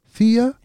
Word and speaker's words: Theia: And it's spelled T-H-E-I-A. Theia? Theia? – It's Theia. It's the old Greek spelling.